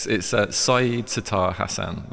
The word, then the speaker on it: Sayed Sattar Hasan: Okay, even I can't pronounce my name correctly, but it's Said Sattar Hasan.